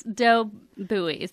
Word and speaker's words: buoys: The dough boys? – Oh. – Yeah. – But is it spelled boys? – It's spelled buoys. Dough buoys.